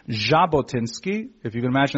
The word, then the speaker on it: Jabotinsky: So his proper legal name is Vladimir Yevgenievich Jabotinsky. The pronunciation is properly a Z sound, which is actually a specific letter in the Cyrillic alphabet.